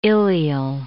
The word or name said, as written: ilial